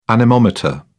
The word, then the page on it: anemometer: British English